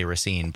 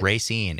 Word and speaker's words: Racine: Also had the smartness and the quickness to call out Racine, Wisconsin, and knows how native Raciners pronounce it even. – Even other people around the state often say Racine, but right. Racine, if you live there, is how you say it.